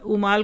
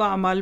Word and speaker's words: उमाल: देखिए आप अरबी में आप उमाल को अमाल पढ़ लें तो मानी बदल जाएंगे, अमाल पढ़ लें अलिफ बन जाएगा, इसलिए प्रोनाउंसिएशन बहुत अहम होता है उर्दू पढ़ते हुए।